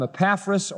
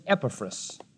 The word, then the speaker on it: Epaphras: We call him Epaphras, or if you really wanted the Greek pronunciation, Epaphras. But Epaphras sounds nicer.